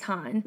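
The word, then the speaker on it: HONNE: Well, I wanted to look up how to pronounce the group because I wasn't sure because it's H-O-N-N-E, all caps. I was like, well, I'm not sure. So we looked it up and it's pronounced Han.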